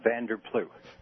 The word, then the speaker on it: Van der Ploeg: It's actually Van der Ploeg. – Van der Ploeg. – Silent G. – Silent G. – Actually, you pronounce it the way they do in Holland.